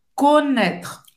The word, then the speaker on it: connaître: Olha, o E no final não é pronunciado. Connaître.